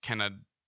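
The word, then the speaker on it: Kaneda: There's a distinct pronunciation in the Japanese, you know, edit or the Japanese version of the movie where it's Kanada.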